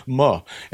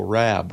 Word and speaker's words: Moorabbin: First syllable "ma," as in much pickup truck is stuck in the mud. Second syllable "rab," rob. Uh, this one's he says it's pretty straightforward. Liam and Sharon, no, it's never straightforward when it comes to me mispronouncing things. The third syllable "bin," as in bin chicken.